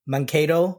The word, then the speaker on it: Mankato: This happened in, tell me if I'm pronouncing sure. this wrong, Mankato, Minnesota? Yeah. Sure. All right. So Mankato. All right.